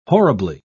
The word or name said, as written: horribly